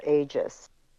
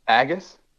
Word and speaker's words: Aegis: And then he went and worked on, later in his career, a system called Aegis. – Wow. – Agus? Spelled the same way, right? – No, A-E-G-I-S.